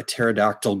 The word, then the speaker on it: pterodactyl: Why can't you hear a pterodactyl going to the bathroom? – I don't know. Why can't you hear a pterodactyl going to the bathroom? – Because the P is silent.